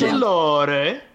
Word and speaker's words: Mandalore: Yes, that's how you pronounce it. So I'm glad you brought this up so you can just show how everyone is to pronounce it. – It's a planet. – Mandalore.